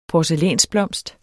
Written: porcelænsblomst

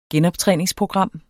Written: genoptræningsprogram

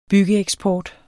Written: byggeeksport

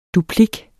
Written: duplik

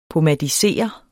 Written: pomadisere

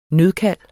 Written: nødkald

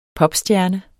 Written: popstjerne

